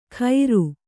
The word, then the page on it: ಖೈರು: khairu